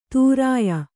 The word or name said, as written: ತುರ್ಯ